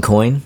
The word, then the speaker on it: Coyne: Wayne Cone? How do you say his last name? – Wayne Coyne. – Coyne? – I think. – C-O-Y-N-E.